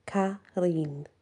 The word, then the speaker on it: Qarin: Q-A-R-I-N, pronounced Karin.